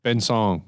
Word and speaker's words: Ben Song: It was supposed to be Ben Seong, but they thought people would not be able to pronounce that as Song. So they literally phonetically wrote it S-O-N-G, which I'm like, come on.